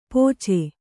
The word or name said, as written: ಪೋಚೆ